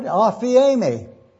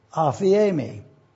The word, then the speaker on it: aphiemi: Didn't you want to know how to pronounce that word? Afiemi. Let me spell it again. A-P-H-I-E-M-I. Afiemi. It's a word which means forgiven.